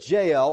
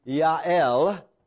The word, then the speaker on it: Yael: So here is Jael, or in the Hebrew, Yael.